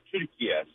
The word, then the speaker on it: Türkiye: The way Turkish people pronounce it is actually the way it's said, Türkiye.